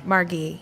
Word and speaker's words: Margie: And they said that there's not really a way to spell that name the way I want it pronounced, but we got it as close as you can. – Margie. – So, Margie, yeah.